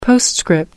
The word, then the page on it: postscript: American English